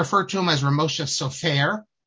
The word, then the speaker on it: Sofer: His name is Sofer. You don't even put the accent on the last part, because he himself pronounced, and his family always pronounced it with the accent on the first part.